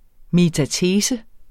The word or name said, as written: METATESE